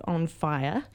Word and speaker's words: Fye: It's called On Fire, but it's spelt F-Y-E.